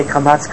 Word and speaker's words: kamatz: What is the difference between a kamatz and a patach? Right? Kamatz is an aw sound.